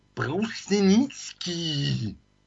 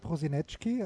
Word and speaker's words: Prosinecki: sondern auch vom Herrn Brusenitzki. Brosenitzki, also das war Robert Prosinecki, äh, wie mhm. auch immer man ihn ausgesprochen hat, einer meiner Lieblingsfußballer aus dem ehemaligen Jugoslawien.